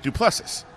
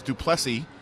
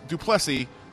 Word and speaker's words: du Plessis: Came out afterwards, and he said, hey, all I want to do with this post-fight is make sure that everybody understands my name is du Plessis.